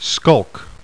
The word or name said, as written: SKULK